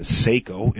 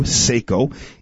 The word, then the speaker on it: Saeco: It's a super automatic espresso machine, and it's a Seiko, and it's spelled S-A-E-C-O, Seiko.